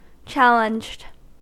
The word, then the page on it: challenged: American English